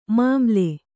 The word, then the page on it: ಮಮ್ಮಲ: mammala